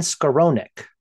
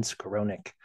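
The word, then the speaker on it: Skowronek: And Ben Skoronik, I think is how you pronounce it, Ben Skoronik.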